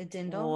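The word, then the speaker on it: dirndl: Whoa, okay, a dindle? – I am obsessed. – Yes. There is an R in the word, but I'm pretty sure the R is silent. So it's a dindle.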